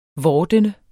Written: vordende

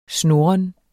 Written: snurren

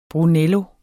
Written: brunello

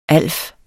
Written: alf

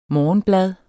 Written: morgenblad

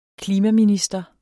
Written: klimaminister

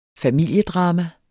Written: familiedrama

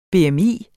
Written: BMI

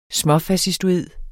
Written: småfascistoid

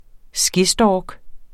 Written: SKESTORK

